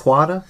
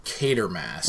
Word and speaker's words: Quatermass: Is that how you say it? Quata? – It's actually Quatermass.